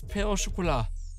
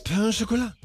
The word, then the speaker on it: Pain au Chocolat: Das ist das Pain au Chocolat. – Das Pain au Chocolat? Ja. Ich bin gar nicht so dumm. – Nein, du hast alles richtig gesagt.